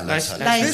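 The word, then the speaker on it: Laeiszhalle: Wie spricht man das aus? – Leis. – Da am Brahmsplatz. In diesem wunderschönen. – Die Leishalle, ne, oder? – Ja, Leishalle. – Wird bloß so komisch geschrieben.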